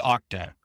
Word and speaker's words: Okta: Is it octa or octa? – I had to learn that as well. It's octa.